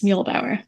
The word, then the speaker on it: Muehbauer: Did I say that correctly? – Yes, Neubauer.